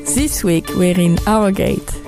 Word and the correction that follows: Harrogate: We are in Harrogate.